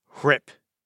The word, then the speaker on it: RHIP: And so the mnemonic that I use with my students is R-H-I-P. Risk, habit, identity, power. – R-H-I-P. It's pronounced RIP. The H is silent.